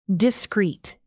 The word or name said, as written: discreet